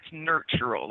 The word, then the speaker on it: Nurtural: And I just have to correct your pronunciation just a tad. That's nurtural, like to nurture something.